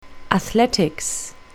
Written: athletics

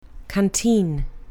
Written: canteen

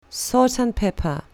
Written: salt and pepper

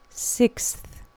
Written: sixth

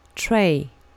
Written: tray

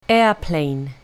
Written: airplane

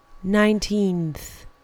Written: nineteenth

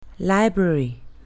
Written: library

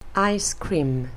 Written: ice cream

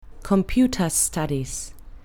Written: computer studies

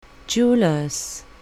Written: jeweller's

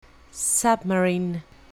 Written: submarine

